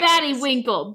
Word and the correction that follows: Baddie Winkle: Baddie Winkle.